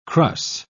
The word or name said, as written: crus